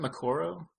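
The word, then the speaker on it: Moqorro: It might have been Morocco, if that's how you say it. The big one that's a... – Yeah, I think it's... Isn't it Makoro? – Makoro, thank you. Yes, Makoro.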